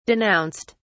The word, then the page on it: denounced: British English